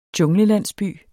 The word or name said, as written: junglelandsby